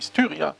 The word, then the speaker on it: Styria: Die prominentesten sind wahrscheinlich die Styra, Styra, Styra, ich möchte es nicht falsch aussprechen, jetzt habe ich es garantiert falsch ausgesprochen, die Styria, Styria Media Group, die praktisch vollständig in kirchlicher Hand liegt und zwei bedeutende Tageszeitungen herausgibt, die Kleine Zeitung und die Presse.